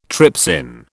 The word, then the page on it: trypsin: British English